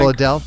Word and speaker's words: Bledel: Alexis Bledel, is that pronounced right? – Alexa Bledel, I think. – Bledel, thank you.